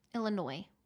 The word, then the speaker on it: Illinois: Illinois. The S is silent.